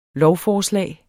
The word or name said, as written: lovforslag